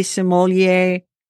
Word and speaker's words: sommelier: But anyway, I did learn that there's something called a honey sommelier. – Yeah. – Did I say that right? – You did.